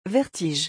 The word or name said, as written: vertige